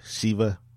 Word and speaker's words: Shiva: And today we are talking about Fear, The Walking Dead, Season 2, Episode 7, entitled Shiva, and it was the mid-season finale. – Right. But there's a couple of ways to pronounce it. It's Shiva.